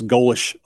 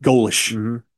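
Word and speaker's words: Golesh: That's, you know, two different head coaches, and now – the Alex Golish, I believe is how you pronounce it, Golish, Comrade Golish over there.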